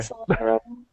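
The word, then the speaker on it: Solatorobo: Was it Sola Torobo? – Yeah, I think you said it right. – Okay. – Sola Torobo.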